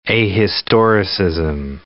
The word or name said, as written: ahistoricism